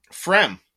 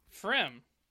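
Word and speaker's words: Pfriem: I am drinking, I don't even know how to pronounce this, man. It's P-F-R-I-E-M. – Frem. – Frem.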